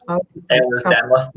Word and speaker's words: Ehlers-Danlos: How do you say Ehlers-Danlos syndrome? – Ehlers-Danlos syndrome. – Yeah, that's it.